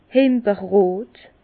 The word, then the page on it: himberrot: Bas-Rhin; Herrlisheim